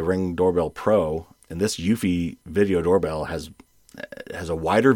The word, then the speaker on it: Eufy: I replaced it with the, I think it's pronounced Eufy, E-U-F-Y, which is an offshoot of the Anker brand.